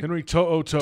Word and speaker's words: To'o-To'o: Okay, so Toyo, did I say that right? – Henry To'o-To'o. – To'o-To'o. – To'o-To'o. – Okay.